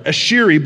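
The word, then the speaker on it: Asheri: Ashiri blessing. It's A-S-H-E-R-I.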